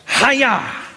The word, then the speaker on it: haya: Now there's a remarkable little bit in this, that the verb, the Hebrew verb to be, to exist, is the verb haya. Sounds like a karate chop. Haya.